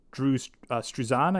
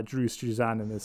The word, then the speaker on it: Drew Struzan: For those who aren't familiar, all the art was actually done by Drew Struzan, I think his name is pronounced.